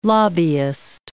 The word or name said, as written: lobbyist